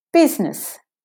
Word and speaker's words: business: The S is saying Z. Biz-ness. Business.